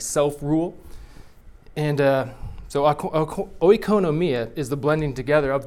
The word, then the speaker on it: oikonomia: oikonomia.